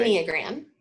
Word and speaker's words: Enneagram: Engram, right? The Engram, how do you pronounce that, right? – Enneagram. – Yeah, Enneagram. Okay, right? Like I just can never get the syllables right.